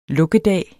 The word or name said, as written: lukkedag